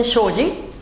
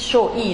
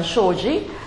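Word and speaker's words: Shoji (生死): Shoji mit zwei Schriftzeichen, Shoji, also wie eine englische Aussprache, Shoji, nicht Shoi, sondern Shoji.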